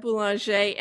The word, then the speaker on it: Boulanger: Did I say your name correctly? – You did, Robin. It is Deb Boulanger.